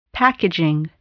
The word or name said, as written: packaging